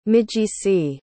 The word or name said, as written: migisi